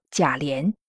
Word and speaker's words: 贾琏: 因为古人认为祭祀仪式可以上达天听，所以敛字又引申为连接，后来便和连接的连字通义，所以也可以读作连。无言的节目里都是遵照这一点，以及八七版《红楼梦》电视剧的通用读法，读作贾琏。